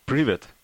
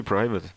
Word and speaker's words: Privet: How do you say it? – Privet. – Privet. – Well, you can say private, which is... – Private. – Easier. – Oh, private. – To pronounce. I'm not particular. – Okay. – But it P-R-I-V-E-T. You can pronounce it as private or pronounce it as private.